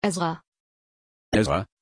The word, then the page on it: ezra: German